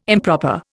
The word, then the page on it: improper: Indian